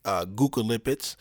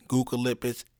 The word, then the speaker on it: Gugulipids: You can take Guculipids, which is spelled G-U-G-U-L-I-P-I-D-S, Guculipids and red yeast rice.